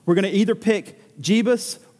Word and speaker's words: Jebus: Is it going to be the town of Jebus? I'm not mispronouncing Jesus, that's what it's pronounced J E B U S.